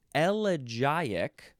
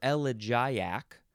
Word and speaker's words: elegiac: Next word is elegiac, or elegiac, or elegiac, or elegiacal. Any one of those is fine. It is spelled E-L-E-G-I-A-C.